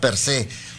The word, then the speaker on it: per se: Fíjate, acento en la E, guinda per se.